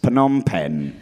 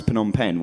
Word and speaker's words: Phnom Penh: But if you ever need a tip on how to pronounce Phnom Penh, what you do is, you know that rim shot sound effect you get whenever anyone tells a shit joke? That ba-dum-tsh, that thing. Imagine somebody doing that on a rubber drum kit. That's how you pronounce Phnom Penh. That's how you do it.